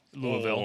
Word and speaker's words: Louisville: I learned that is officially how you pronounce it. Our friend Pico says you have to pronounce it like you have gravel in your mouth, Louisville. Whoa.